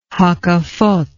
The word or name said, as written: hakafoth